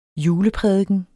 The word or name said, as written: juleprædiken